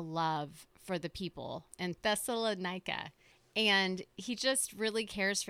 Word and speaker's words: Thessalonica: And so this is Paul later on, and he's writing to the people of Thessalonica.